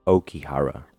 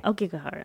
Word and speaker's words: Aokigahara: It's actually pronounced... – Aokigahara. – I'm sorry. – Aokigahara. I am so sorry. I didn't look up the pronunciation. – Yeah, you never do your homework. – I do. It's just usually in Japanese you pronounce things as they are, like Spanish.